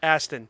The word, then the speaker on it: Astin: I'm going to go back and just clip Astin.